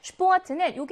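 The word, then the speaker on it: Sport: Sport처럼 여러분이 복자음이 나오게 되면 가끔 헷갈려 하는데 Sport는 여기 나와 있는 S는 쉬 발음을 내서 주의해야 하는 그 발음이고요. P는 ㅂ로 발음을 합니다.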